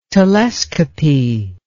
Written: telescopy

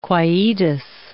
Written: quietus

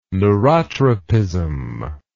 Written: neurotropism

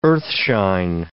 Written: earthshine

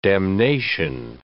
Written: damnation

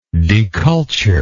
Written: deculture

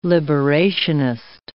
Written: liberationist

